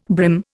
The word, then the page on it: brim: Indian